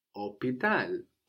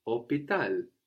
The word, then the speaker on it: hôpital: Hospital in French is hôpital, hôpital. H O P I T A L. So we have the silent H again, like in habiter, and there's no S here.